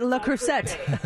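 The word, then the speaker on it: Le Creuset: La Crusade cookware. – Oh, is that how you say it? La Crusade? Yeah, I always call it La Crusette.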